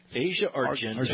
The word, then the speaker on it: Argento: I don't know how to say it. – Asia Argento. – Argento, yeah.